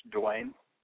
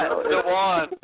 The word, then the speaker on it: Duane: Is it like Dwayne or Dwayne or Dwan? – It's like Dwan. It's like Dwan. D-U-A-N-E.